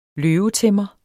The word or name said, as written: løvetæmmer